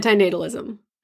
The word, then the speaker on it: antinatalism: I was saying antinatalism.